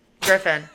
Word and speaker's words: Griffin: I think you said my last name wrong. – What did I say? – Griffith. – Griffin.